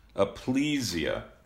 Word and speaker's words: Aplysia: So, Michael, tell us, first of all, did I pronounce any of those words correctly in the study? – That sounded pretty good, but I'm not an expert on how to pronounce slug and or snail scientific names, so probably we should ask somebody else to make sure we have it right. – Aplysia.